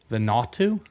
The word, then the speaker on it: Vanuatu: V-A-N-U-A-T-U. – Vanuatu? – Vanuatu.